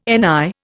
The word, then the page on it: NI: Indian